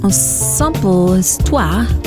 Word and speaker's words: Ensemble Histoire: Ivory Corporation mit Ensemble Histoire. Perfekt in Französisch natürlich ausgesprochen.